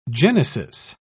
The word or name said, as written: genesis